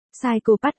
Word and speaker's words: psychopath: Psychopath, anh nó cố gắng đọc thật rõ cho nó nghe, vậy còn chữ. P, psychopath, ở đầu vứt đi đâu, nó chu mỏ bướng bỉnh chữ.